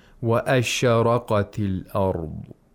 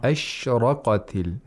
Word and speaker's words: ashraqatil: The way that Sheikh Hindawi, he pronounced the sheen, it's not wa ashraqati al-ard. It's wa ashraqatil, ashraqatil, not ashraqati.